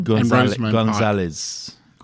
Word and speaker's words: Gonzalez: Gonzalez.